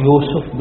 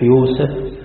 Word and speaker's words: يوسف: ويوسف تقرأ يوسف بالضم كما هي في القرآن، وتنطق كذلك بالفتح يوسف، كذلك بالكسر يوسف، كل ذلك لغة.